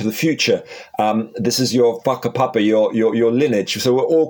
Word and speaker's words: whakapapa: Whakapapa is spelled with a WH, but in Maori, WH is the letter F. Sounds like an F.